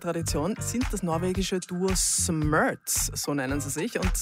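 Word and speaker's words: Smerz: We pronounce it Smerts. But it's up to you.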